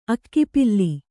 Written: ಅಕ್ಕಿಪಿಲ್ಲಿ

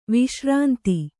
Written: ವಿಶ್ರಾಂತ

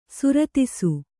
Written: ಸುರತಿಸು

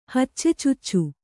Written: ಹಚ್ಚೆಚುಚ್ಚು

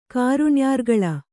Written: ಕಾರುಣ್ಯಾರ್ಗಳ

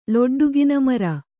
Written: ಲೊಡ್ಡುಗಿನಮರ

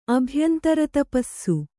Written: ಅಭ್ಯಂತರತಪಸ್ಸು

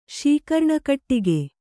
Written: ಶೀಕರ್ಣಕಟ್ಟಿಗೆ

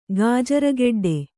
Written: ಗಾಜರಗೆಡ್ಡೆ

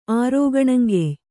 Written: ಆರೋಗಣಂಗೆಯ್

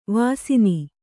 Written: ವಸ್ನ

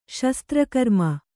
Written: ಶಸ್ತ್ರಕರ್ಮ

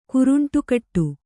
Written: ಕುರುಂಟುಕಟ್ಟು

